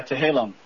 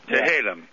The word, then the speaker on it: Tehillim: You got more than, let me see if I could pronounce this right, Tehillim Psalms? – Yeah, Tehillim. – Tehillim.